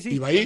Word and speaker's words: Ibai: Ibai o Ibai Llanos, corríjame. – Es Ibai, ¿no? – Sí. – Ibai.